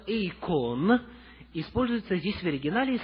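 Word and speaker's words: эйкон (εἰκών): Древнегреческое слово «эйкон» – ударение на второй слог.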